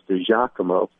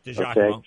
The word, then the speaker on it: DiGiacomo: But Giovanni, and his last name, by the way, is pronounced DiGiacomo. – DiGiacomo, okay. – Obviously, you're not Italian.